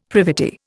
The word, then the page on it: privity: Indian